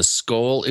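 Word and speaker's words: Scole: One of our posters, Canicaris, is it asking about your take on the Skoll experiments, S-C-O-L-E. I'm not sure, sure if I'm pronouncing it correctly. – Yeah, that's correct.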